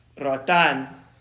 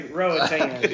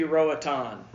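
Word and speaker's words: Roatán: Roatan. – Okay, I'm going to go back to how I was doing. So, most gringos say Roatan. – Yeah. – But it should be Roatan. – Roatan.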